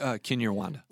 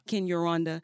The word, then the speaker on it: Kinyarwanda: Kinyarwanda. – Kinyarwanda.